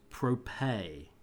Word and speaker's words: Prope: My glorious company, Prope. – Propay. – What? – It's pronounced Propay.